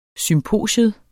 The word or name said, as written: SYMPOSION